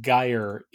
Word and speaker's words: gyre: It is apparently pronounced gyre in the poem, because at the time, if you were talking about like a gyroscope or something, you pronounced it gyroscope, at least in England. So okay. – The intention there was to pronounce it with a hard G.